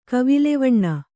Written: ಕವಿಲ್ವಣ್ಣ

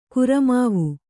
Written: ಕುರಮಾವು

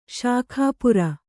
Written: ಶಾಖಾಪುರ

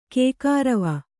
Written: ಕೈಕುರುವೆ

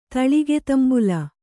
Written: ತಳಿಗೆತಂಬುಲ

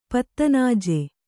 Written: ಪತ್ತನಾಜೆ

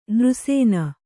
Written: ನಿರಸನೆ

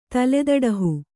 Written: ತಲೆದಡಹು

